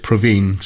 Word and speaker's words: Provine: Dorothy Provine. I think she pronounced it Praveen, so I better go with that one.